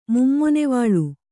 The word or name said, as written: ಮುಮ್ಮೊನೆವಾಳು